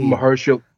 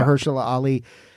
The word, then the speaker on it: Mahershala Ali: I can't pronounce his name. Mahershala Ali. – Mahershala. – Yeah. Mahershala Ali.